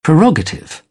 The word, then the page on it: prerogative: British English; t